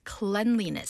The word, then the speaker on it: cleanliness: How can you pronounce that again? – Yes, cleanliness. – 그렇죠. Clean이지만 명사형으로 쓸 때는 약간 중간에 발음이 바뀝니다. Cleanliness.